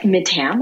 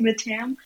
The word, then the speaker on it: Mattam: Yeah, Mattam, I go. But actually, yeah, so it's Mattam. – Mattam. That's the sort of anglicized version. – It's the anglicized version.